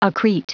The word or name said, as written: accrete